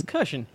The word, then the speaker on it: Kirshen: It's Kirshen, yeah. – Yeah, I know, but you never know. – It's said the way it's spelled, sort of, but not quite.